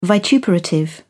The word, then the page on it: vituperative: British English